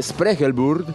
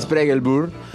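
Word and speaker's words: Spregelburd: Rafael Spregelburg. ¿Lo dije bien, Ellensweig? – Perfecto. – Spregelburg, con dirección y puesta en escena de Hernán Ledesma.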